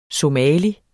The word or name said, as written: somali